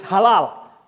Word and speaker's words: halal: The word praise in Hebrew is halal. It's spelled H-A-L-A-L. H-A-L-A-L.